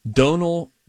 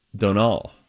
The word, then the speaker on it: Domhnall: It's spelled D O M H N A L L. Okay. – Spounced, uh, pronounced rather Donal or Donal.